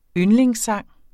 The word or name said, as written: yndlingssang